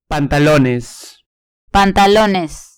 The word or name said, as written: pantalones